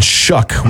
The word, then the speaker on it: Shough: What like country of origin uses O-U-G-H with the uck sound? – Yeah, that's weird to me. – Nobody knows. – You could have given me 35 guesses of how to pronounce his last name, and Shuck would not have come out of my mouth with S-H-O-U-G-H.